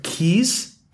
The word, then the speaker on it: Caius: Like that, I can kind of see, but I'm sorry. Keys. C-A-I-U-S. Keys. – How is that meant to be pronounced then? – Caius. I went to school with a guy called Caius, spelled that way.